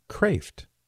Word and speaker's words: Kreeft: And I know his name looks like it would be pronounced Kreeft. That's how I would pronounce it. But I know Peter, and it's pronounced Kreeft. K-R-E-E-F-T.